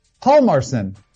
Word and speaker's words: Hjalmarsson: Now, the J, historically, you don't pronounce. So I'm going to say Nicholas Hjalmarsson.